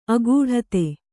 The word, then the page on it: ಆಘಟಿತ: kannada; āghaṭita